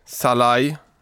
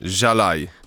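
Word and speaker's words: Szalay: Vill du uttala hans namn? – Oj, han är ju ungrare va? Chalai. – Ja. – Chalai. – David Chalai säger vi.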